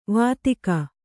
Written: ವೈದಿಕ

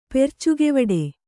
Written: ಪೆರ್ಚುಗೆವಡೆ

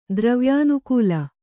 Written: ದ್ರವ್ಯಾನುಕೂಲ

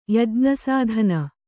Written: ಯಜ್ಞಸಾಧನ

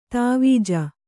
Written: ಧ್ವಜ

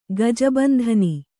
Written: ಗಜಬಂಧನಿ

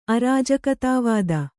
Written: ಅರಾಜಕತಾವಾದಿ

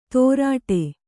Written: ತೊರಟೆ

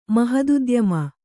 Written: ಮಹದುದ್ಯಮ

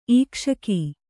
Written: ಈಕ್ಷಕ

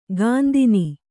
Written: ಗಂಧಿನಿ

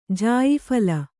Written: ಜಾಯಿಫಲ

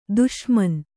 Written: ದುಸ್ಮಾನ

